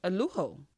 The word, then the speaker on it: Alujo: Oh, Aluju. Oh. – Alujo. – Oh. – The J is silent, Teddy. Alujo.